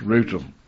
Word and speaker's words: Rotham: Rotham. But it's pronounced Rootham.